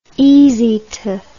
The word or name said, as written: easy to